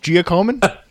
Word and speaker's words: Giacomin: How many total wins did Eddie Jockeman have while playing with the Rangers? – Wait, are you sure about that pronunciation of his name? No, I think it's Giacomin.